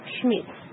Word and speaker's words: Schmidt: Schmidt. – Is that your family name? – Yes. – Do you spell that S-H-M-I-T? – No.It's spelled S-C-H-M-I-D-T.